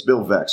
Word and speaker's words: Veeck: His name is Bill Veeck, and it's spelled V-E-E-C-K, and that's important because he had a book written about him way back when called Veeck as in wreck. You know, as in that's how you pronounce it, because it would be weird to like V-E-E-C. How do you pronounce that? So it's Bill Veeck.